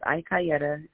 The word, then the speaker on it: Ikiyette: Yes, so it's Ikayetta, IKIYETTE.